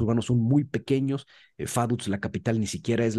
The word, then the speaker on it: Vaduz: Esa V chica se pronuncia como F y el corredor de autos Vettel, no es Vettel. Eh, y la Z, pues es una TS. Entonces, su pronunciación es Faduts, ya lo saben ahora.